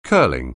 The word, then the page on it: curling: anglais